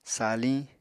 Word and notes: saliñ: apatani

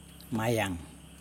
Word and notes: majaN: Yokoim

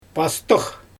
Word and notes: paastyx: Xyzyl

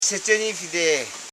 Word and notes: sechenifide: se.che.ni.fi.de; Koro

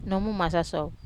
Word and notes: masaso: Wamesa

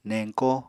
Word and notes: neñko: Apatani

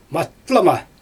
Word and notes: matlama: Xyzyl

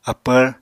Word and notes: apvr: Apatani

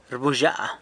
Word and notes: rbuzhya: sanlucasquiavini

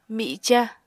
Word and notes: mica: Apatani

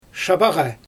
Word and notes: ʃabaɣa: Xyzyl

